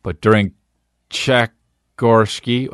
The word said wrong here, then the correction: Tchaikovsky: How do you say it? Tchaikovsky. Oh, well, that's an R. Tchaikovsky, right. I know Tchaikovsky.